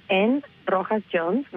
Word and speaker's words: N Rojas Jones: It's N Rojas Jones, which is spelled as N as in Nancy, R-O-J-A-S-J-O-N-E-S, at the chicagoschool.edu.